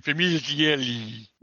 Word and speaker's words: Famiglietti: That's how you pronounce it in Italian. Famiglietti.